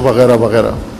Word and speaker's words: وغیرہ: اردو لہجے میں جب تلفظ کرتے ہیں تو کہتے ہیں وغیرہ وغیرہ،